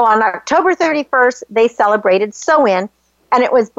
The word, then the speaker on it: Samhain: Like, so there was an ancient Celtic festival called Samhain, and it's actually spelled Samhain, but it's pronounced Samhain.